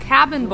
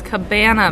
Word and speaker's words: cabana: It's not cabin boys, it's cabana boys.